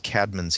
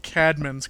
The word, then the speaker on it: Caedmon: And you can be smarter than your friends by referring to the coffee house rock band as Caedmon's Call rather than Caedmon's Call. – Well, it's not an A and an E. It's one letter. It's called an ash, and you say it like ah.